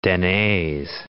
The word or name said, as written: Denes